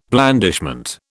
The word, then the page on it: blandishment: British English